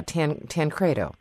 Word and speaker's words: Tancredo: How about Tom Tancredo? Did I say that correctly? – Yeah. You know, well, that actually brings up another point, but yeah, Tancredo.